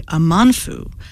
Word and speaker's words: Amanfu: And Ruby Abamfu, did I say it right? Nope. Ruby Amanfu was the Ruby.